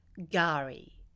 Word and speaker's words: K'gari: It's spelt K-G-A-R-I, but you'll hear it being said with a silent K, Gari.